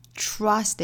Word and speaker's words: trust: 不要很轻易的就读成我们汉语拼音里面那个吃的发音，不是 trust，应该是 trust，错误的发音是 trust，正确的发音应该是 trust。